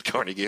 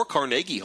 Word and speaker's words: Carnegie: Well, lots of people pronounce it Carnegie, and lots of people, including the announcers on NPR, pronounce it Carnegie. And both sides believe that they are right and will be very, very cross with you if you disagree with them on pronunciation.